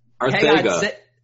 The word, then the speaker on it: Arcega: Arcega. – Say his name right.